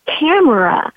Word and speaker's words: Tamara: And so my mom let me know that my name could be pronounced Tamara, Tamara Early.